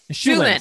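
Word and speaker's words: Shu Lien: And starring Chow Yun Fat, who plays Li Mu Bai, who is the Swordmaster, Michelle Yeoh, who plays Shen Lu or Lu Shen. – Shulin. – Thank you. – I wrote it phonetically. – Thank you so much. Shulin.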